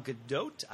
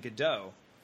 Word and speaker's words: Gadot: But since Gal Gadot is Israeli and not French, it's not a silent T. It's a hard T.